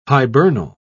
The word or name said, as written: hibernal